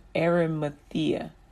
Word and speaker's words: Arimathea: Joseph of Arimathea, Arimathea, am I saying that right? Uh, Ari, Arimathea, Arimathea, there we go,